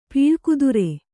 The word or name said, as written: ಪೀಳ್ಕುದುರೆ